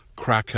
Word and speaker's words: craic: So, what's happening at the Crack House? That's spelled C-R-A-I-C, and it means good cheer, good conversation, humor, and gemütlichkeit.